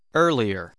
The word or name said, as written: earlier